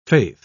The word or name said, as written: faith